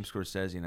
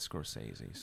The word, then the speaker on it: Scorsese: Yeah, he does say his name Scorsese, not Scorsese.